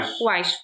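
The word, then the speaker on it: Weisz: Wise w końcu ustaliliśmy.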